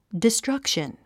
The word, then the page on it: destruction: distrʌ́kʃən; ディストラァクション